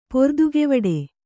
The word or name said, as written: ಪೊರ್ದುಗೆವಡೆ